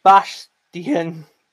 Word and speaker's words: Bastian: It's pronounced Bastian.